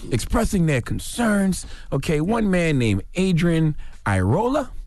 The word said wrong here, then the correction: Iraola: I think I pronounced his last name right, Iraola, was sharing a story with parents and school administrators about how racism in the school had been affecting his child.